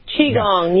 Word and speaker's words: Qigong: I'm pronouncing it correctly? Qigong? – Yes, Qigong, yes.